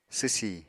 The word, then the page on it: swsi: apatani